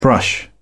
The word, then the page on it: brush: British English